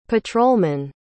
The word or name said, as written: patrolmen